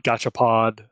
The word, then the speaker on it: Gachapon: And then kind of going back to Gachapon a little bit, I think I might have said it three different ways too, because I'm terrible at pronunciation. But Gachapon.